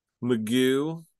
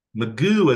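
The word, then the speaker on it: Mcgough: They've also brought out Alex McGuff. – Magoo is, I believe, how they say it. It looks like McGuff, perhaps, but it's, yes. But it's Magoo. – Magoo.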